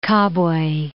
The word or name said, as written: Kabwe